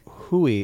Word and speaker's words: Rui: Hui? – Hui. It's spelled R-U-I.